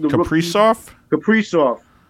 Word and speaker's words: Kaprizov: Minnesota is with the Kaprizov. I may be getting his name wrong. – Kaprizov.